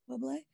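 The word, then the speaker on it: Zeba Blay: Is that how you say her name? – Yes. – Ziba Blay.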